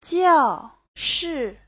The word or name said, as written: jiao4shi4